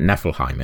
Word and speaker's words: Niflheim: I can tell you that Nibelheim's not a Norse place, but Niflheim is. It's definitely alluding to... – Niflheim is, yeah, you're right.